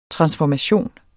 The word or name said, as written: transformation